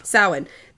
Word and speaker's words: Samhain: It's like S-O-W-I-N. – Okay. – But it's not. That's not how it's spelled. – No. – It's S-A-M-H-A-I-N, but yeah. It's pronounced S-O-W-I-N. Samhain.